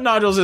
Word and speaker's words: nodules: She doesn't have noodles. Hopefully she doesn't have noodles in her throat. I mean, I thought nodules as well, but for some reason I guess trying to Japanese-ize it and pronunciation.